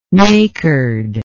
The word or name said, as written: nacred